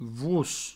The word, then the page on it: vos: vouos